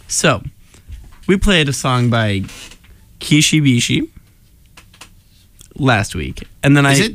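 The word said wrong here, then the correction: Kishi Bashi: I call it Kishibashi. – Well, oh, that's totally... there's an A, not an I. – There it is. – That's how you pronounce it.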